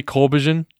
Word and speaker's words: Corbijn: Is it really Corbijn? – Yes. – I'm just going to say the J is silent.